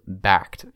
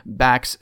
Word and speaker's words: Bakkt: is creating a crypto platform called Bakkt. It's spelled B-A-K-K-T.